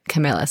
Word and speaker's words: Camila: My name is in Spanish, so it's pronounced Camila, right. But in English, I'm used to people pronouncing it Camilla. So just whatever you want.